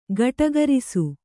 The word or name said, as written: ಗಟುಕರಿಸು